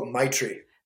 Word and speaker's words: Maitri: M-A-I-T-R-I? How do you pronounce that? – Oh, Maitri.